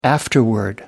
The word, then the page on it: afterward: British English